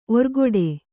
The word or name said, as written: ಒರ್ಗೊಡೆ